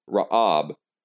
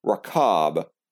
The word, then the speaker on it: Rahab: The pronunciation is Ra'ab, because there is no H character in Greek, breathing marks excluded. But here in Matthew, the spelling is Rho Alpha Chi Alpha Beta, with Chi stuck in the middle there. The pronunciation then is Rakab.